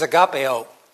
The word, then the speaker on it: agape: Now, I'm going to use one word, but it's got two different pronunciations. One is agapeo. In the command to love one another, it's a verb, and it means agapeo. That means the activity of loving. Now, there's the other word, agape, and I think, is that the root of agapeo?